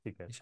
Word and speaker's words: Zeekr: no sé cómo se pronuncia muy bien, Zeekr y Xiaomi. – Seeker, sí, Zeekr, sí.